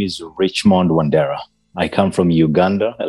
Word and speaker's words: Wandera: Well, Richmond Wandera, am I pronouncing that right? – That's correct.